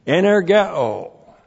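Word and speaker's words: energeō: Energeo. E-N-E-R-G-E long O. Energeo.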